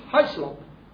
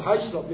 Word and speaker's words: Hislop: That's the reason why, if you look into his high slope, or Hislop, Hislop, we pronounce that, to Babylon.